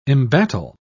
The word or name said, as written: embattle